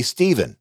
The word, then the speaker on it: Stephen: My name is pronounced Esteban in Spanish. In English, we say Stephen, or Steve for short.